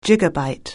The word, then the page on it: gigabyte: anglais